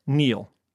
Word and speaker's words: kneel: K-N-E-E-L, Neil.